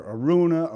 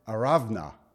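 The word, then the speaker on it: Araunah: Aruna, Arana. You know what? It's Aravna. That's how they say it in Israel.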